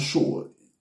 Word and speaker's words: Sheol: Now, hell there in the Hebrew is the word sheol. Let me spell it for you. S-H-E-O-L. Sheol.